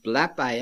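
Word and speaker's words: blepei: And I won't try to burden you with the Greek language, but very simply, when it says that John went and saw, that's a Greek word pronounced blepi, and it means glanced.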